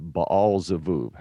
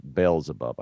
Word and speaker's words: Beelzebub: He said, go inquire of Beelzebub, or Baal-Zebub, however you want to pronounce that. Beelzebub, I guess, is the way we say this in English.